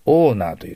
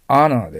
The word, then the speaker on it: honor: H-O-N-O-R の発音ですね。これは私たち日本人が非常に間違うんです。オーナーと言ってしまいます。違います。アナーです。 H-O の発音はどちらかといえば、 O よりもアに近い方の発音ですね。アナーとなります。